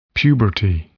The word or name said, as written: puberty